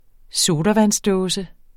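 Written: sodavandsdåse

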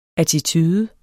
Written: attitude

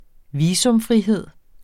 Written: visumfrihed

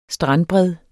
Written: strandbred

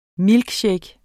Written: milkshake

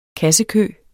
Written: kassekø

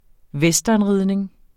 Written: westernridning

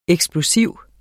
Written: eksplosiv

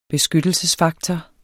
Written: beskyttelsesfaktor

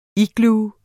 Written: igloo

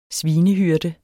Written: svinehyrde

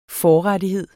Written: FORRETTIGHEDERNES

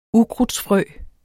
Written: ukrudtsfrø

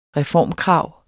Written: reformkrav

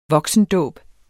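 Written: voksendåb

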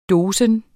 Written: dosis

